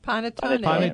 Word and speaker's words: panettone: Motta, it's M-O-T-T-A. – Let me get this straight. Panitello. – Panettone. – Panettone. Yeah. Okay. And how do you spell it? – P-A-N-E-T-T-O-N-E.